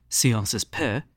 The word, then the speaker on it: Sciences Po: Professors Guriev and Treisman at Sciences Po,